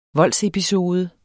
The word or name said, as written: voldsepisode